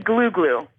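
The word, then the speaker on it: glou-glou: What about, is it glue glue or glau glau? – It's apparently glue glue. – Glue glue.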